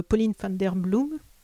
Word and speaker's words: Pauline van der Bloem: Pauline van der Bloem, on prononce comme ça Oui, c'est bien.